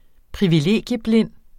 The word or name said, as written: privilegieblind